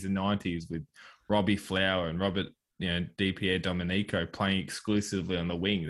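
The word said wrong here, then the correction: DiPierdomenico: Dipier Domenico.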